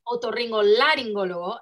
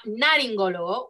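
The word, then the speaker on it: otorrinolaringólogo: I was pronouncing wrong my whole life. I've realized that it's otorrinolaringologo and not naringologo, which is something that my roommates make fun of me all the time.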